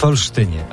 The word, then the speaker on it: Olsztynie: W Olsztynie, o, tak się wymawia.